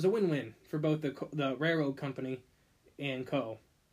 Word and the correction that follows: Coe: I think that's how you say his name, C-O-E. – Yeah, Coe. – Yeah, that's what I would say. I heard one person pronounce it Cole. – Like they forgot the L? – Yeah.